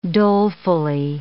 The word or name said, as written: dolefully